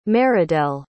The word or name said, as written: Maridel